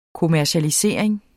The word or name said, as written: kommercialisering